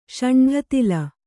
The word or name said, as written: ಷಂಢತಿಲ